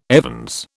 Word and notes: evens: British English

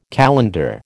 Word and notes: calender: American English

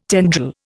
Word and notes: tendril: Indian

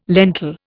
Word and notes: lentil: Indian

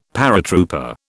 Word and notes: paratrooper: British English

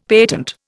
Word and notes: patent: Indian

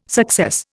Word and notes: success: Indian

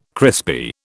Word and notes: crispy: British English

